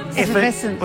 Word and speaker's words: Evanescence: He's the guitarist for Effervescence. – Evanescence. – What's the name of the band? Evanescence. – No, I'm saying it wrong. Say it again.